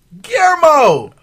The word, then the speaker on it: Guillermo: How you used to pronounce it? – Guillermo. It's so easy. – But it has like a U and an R in it. And two L's.